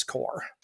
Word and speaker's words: Corps: It's silent S. That's right. That makes no sense as an English. Why does it says corpse? I know it's. It does. It's the French.